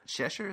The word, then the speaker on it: Cheshire: Cheshire, is that how you say it? – Yep, like the cat.